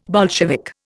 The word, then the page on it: Bolshevik: Indian